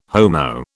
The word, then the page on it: Homo: British English